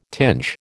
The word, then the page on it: tinge: American English